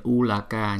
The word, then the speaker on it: Ulaka: Dharani, Bhumi, Kambakabata, Baya, Ulaka, cái này cũng giống như là Ulaka nhưng mà cái chữ L này chúng ta không có đọc là la hẳn hoi mà Ula, Ula, Ulaka, Ulaka, Bata, Ulaka, Bata, Baya, Raja, Raja, Raja, Danda, Danda, Danda.